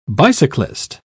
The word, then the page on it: bicyclist: British English